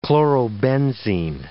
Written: chlorobenzene

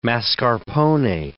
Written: mascarpone

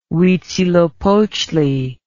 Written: uitzilopochtli

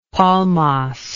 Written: palmas

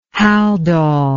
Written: haldol

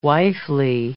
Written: wifely

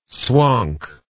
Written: swonk

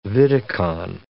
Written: vidicon

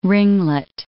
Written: ringlet

